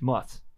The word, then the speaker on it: musth: It's not, but musk. It's spelled M-U-S-T-H. Muth.